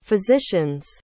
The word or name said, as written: physician's